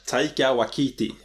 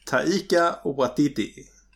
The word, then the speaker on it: Taika Waititi: Taika Waititi. Waititi. – Ja. – Taika Waititi. – Ja. Så heter han.